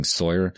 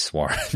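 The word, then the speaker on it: SOIR: But actually, it is SWAR because it's meant to be pronounced SWAR. Yeah, it's meant to be pronounced like the French word for evening, because this is an instrument used to look at the sun setting behind Venus.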